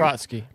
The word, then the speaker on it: Trotsky: how do you pronounce it? Trotsky or what? How do you? – Trotsky. – Trotsky. Sorry. Sorry about that.